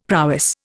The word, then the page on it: prowess: Indian